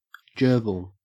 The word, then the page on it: gerbil: Australian English